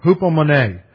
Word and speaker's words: Hupomone: Hupomone. H-U-P-O-M-O-N-E. Hupomone.